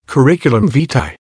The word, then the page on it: curriculum vitae: American English